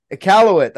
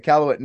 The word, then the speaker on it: Iqaluit: Iqaluit? – Iqaluit. That's how you say it. Iqaluit, Nunavut.